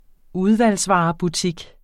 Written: udvalgsvarebutik

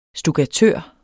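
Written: stukkatør

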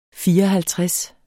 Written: fireoghalvtreds

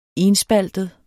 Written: enspaltet

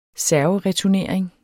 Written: servereturnering

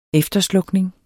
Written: efterslukning

